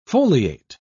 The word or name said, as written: foliate